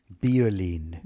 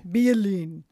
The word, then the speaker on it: Beauline: So Halle Berry is the designer for this packaging, for this new line, Beauline, which is spelled B-E-A-U hyphen L-I-N-E. – Beauline. – Beauline.